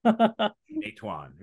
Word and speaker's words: Meituan: you know, and then later on, you know, you had Xiaomi and you had uh, Huawei, and then of course now it's it's Pinduoduo, it's uh, Xiaohongshu, and it just like Meituan, yeah, crazy, right? – Your, so, your Chinese pronunciation yeah. is perfect for these. Companies.